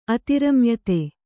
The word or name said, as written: ಅತಿರಮ್ಯತೆ